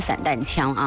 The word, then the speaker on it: 散弹枪: 但是在读炮弹的时候，读的就是应该读散这个音，散弹啊，散弹枪。我们回复一下微信当中的这位叫微风过海，还有临江仙，还有一位呢没有标注名字的这位微信听友。 – 对，所以啊，他这个正确的读音呢就是散弹枪啊。